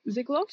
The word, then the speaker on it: Ziegler: Ziegler. – Okay. Maddie Ziegler.